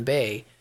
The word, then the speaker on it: Bae: Also, my name, Kevin Bay, my last name is spelled B-A-E. And it picks it up, obviously, as B-A-Y.